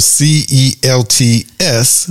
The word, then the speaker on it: Celts: The reason why I struggle with that because it's it's spelled C E L T S, but it's Celts, pronounced Celts.